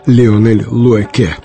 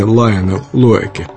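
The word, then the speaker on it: Lionel Loueke: Поэтому правильно его имя произносить Леонель Луэке, а не Лайонел Луэке.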